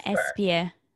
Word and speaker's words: Espir: Deanna Espir.